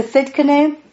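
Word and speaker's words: Tsidkenu: Jehovah Sikkenu. Sikkenu. To Sikkenu? Yeah, thank you, Marilyn Hickey. That's how I learned how to pronounce that word.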